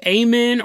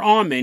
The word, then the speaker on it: Amun: Because then every prayer you say in whatever religion you're in, if it ends with Amun or Amun, because you can pronounce it either way, his name and the word, you're calling out to him.